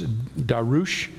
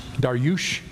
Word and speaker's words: Darius: Sarah has informed me that the actual pronunciation of Darius is Darush. Darush. There you go.